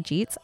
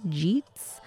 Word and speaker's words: Jeets: Okay, so Sir Jeets asks, I have two questions, but before you answer, my name is pronounced Jeets. I really hope that I am genuinely pronouncing that right, because I'm thinking of like G's, but with a T, Jeets.